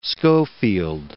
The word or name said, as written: Schofield